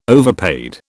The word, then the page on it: overpaid: British English